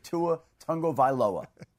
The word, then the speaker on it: Tagovailoa: Tua himself says you pronounce it Tua Tagovailoa, right? So there it is. I think I'm getting it pretty close.